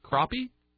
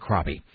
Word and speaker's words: crappie: So the fish is called a crappie. That's how it's pronounced.